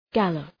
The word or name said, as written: gallop